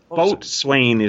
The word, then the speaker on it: boatswain: Boatswain is how it's spelled. It's spelled boatswain, and it's pronounced boatswain.